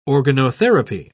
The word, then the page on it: organotherapy: British English